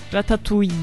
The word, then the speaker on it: ratatouille: Ratatouille. Mesela nasıl okunuyor? – Ratatouille. – Niye o zaman ratatouille diyorlar burada? – Ratatouille diyorlar. Bilmem, Türkçe'ye öyle çevirdiler.